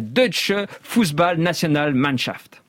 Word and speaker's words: Deutsche Fußball-Nationalmannschaft: aux odes consacrées à la Deutsche Fußball-Nationalmannschaft.